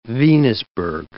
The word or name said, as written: Venusberg